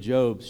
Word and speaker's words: Job: I'll never forget, as a college student, I was in a Bible study, and I called this the book of Job, right? It's spelled J-O-B, by the way. And some Christian people made fun of me. It's the book of Job. Well, it's spelled J-O-B, son.